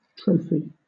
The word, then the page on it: trophy: British English